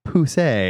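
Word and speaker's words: Pussey!: And then Pussy, which is pronounced Pousse,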